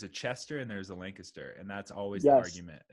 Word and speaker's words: Lancaster: And yeah, guys, if you are in, is it Lancaster, PA, right? – Yep, Lancaster. And you said it right, dude.